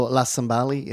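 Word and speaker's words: La Cimbali: But Rancilio, how do you say it? La Cymbali? – Oh, La Cymbali. It's an Italian one, yeah.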